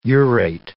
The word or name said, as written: urate